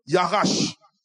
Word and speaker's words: yarash: Mais vous savez, bien aimé, la chose extraordinaire dans sa racine hébraïque, le mot possession veut dire yarash. Ça s'écrit Y-A-R-A-S-H.